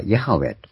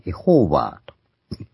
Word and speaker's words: ヤハウェ: エロヒムっていうのは一般に神ということはですね、主っていう訳されたことはヤハウェと。昔はその読み方がわからなかったエホーバーというふうに読んでいったんですけども、これはアドーナイの母音の一部を取りってですね、読み方をアドーナイ主と言う。